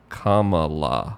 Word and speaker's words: Kamala: La. As in a comma. Kamala.